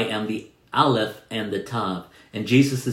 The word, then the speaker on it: Aleph: Can you say Aleph? I spell it A-L-E-P-H, Aleph.